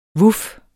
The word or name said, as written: vuf